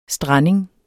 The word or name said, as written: STRANDINGEN